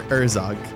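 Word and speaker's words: Urzogz: Urzog. – Yeah. Urzog. Yeah. The last Z is silent. – Silent. – There's a silent Z at the end.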